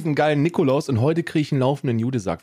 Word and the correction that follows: Jutesack: Nicht zu, vergessen, nicht zu verwechseln mit einem äh, eine falsche Aussprache. Jutesack. Jutesack. Nicht Hodensack. Ganz wichtig. – Nee, nicht, auch nicht Judensack, sondern Jutesack. Ganz, ganz wichtig in der Aussprache in diesem Zusammenhang.